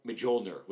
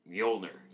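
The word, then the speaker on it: Mjölnir: It was like, because I'm an idiot, years of reading Thor comics, I pronounced it Mjolnir with a hard J. And then seeing Thor, it's Mjolnir. You don't pronounce J.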